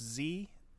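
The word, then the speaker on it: Tzi: I'm gonna say the first name is pronounced Z, T Z I.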